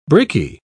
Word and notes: bricky: British English

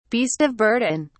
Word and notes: beast of burden: British English

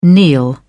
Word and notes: kneel: British English

